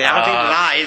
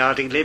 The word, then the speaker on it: Ardingly: It's Ardingly, actually. Ardingly, in Sussex, you always make it lie at the end. – Lie, okay. – A lot of people say Ardingly, but it's a Sussex thing to pronounce the L-Y, an L-Y at the end with a lie.